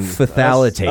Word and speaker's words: phthalates: How do you say that? P-H... – P-H-T-H? – T-H, exactly. That's a hard one, right? – Phthalates. – Phthalates. Yeah.